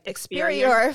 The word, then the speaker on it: Experior: So recently, the company that you're affiliated with, which is Experia, did I pronounce it right? – Experior Financial Financial Group, they reached a huge milestone by hitting 4,000 licensed agencies across North America.